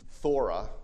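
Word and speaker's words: thora (φθορά): The word there is thora. If you were to spell it with English letters, it would be F-T-H-O-R-A, thora. Hey, you know, it's Greek. What can you? I don't make up these words. Thora.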